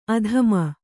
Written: ಆದಿಮ

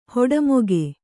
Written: ಹುಟ್ಟಮೂಕ

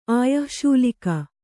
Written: ಆಯಶ್ಶೂಲಿಕ

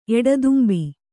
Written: ಎಡದುಂಬಿ

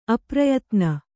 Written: ಅಪ್ರಯತ್ನ

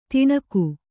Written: ತಣುಕು

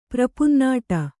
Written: ಪ್ರಪುನ್ನಾಡ

